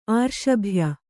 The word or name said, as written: ಆರ್ಷಭ್ಯ